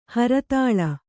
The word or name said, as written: ಹರಿತಾಳ